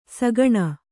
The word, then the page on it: ಸುಗುಣಿ: suguṇi